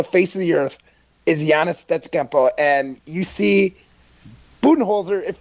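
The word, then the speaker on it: Giannis Antetokounmpo: Giannis Stetskempo. – That was a lot better. Yeah, you said it right that time.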